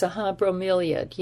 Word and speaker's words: bromeliad: Yes, bromeliad, yeah.